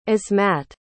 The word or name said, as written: ismat